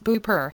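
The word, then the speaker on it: Beaupré: In the Manual of Devotion to Good Saint Anne, Delaware Beaupre, pronounced Beaupre, in the province of Quebec, and bearing the imprimatur of E.A.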